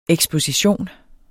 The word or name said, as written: eksposition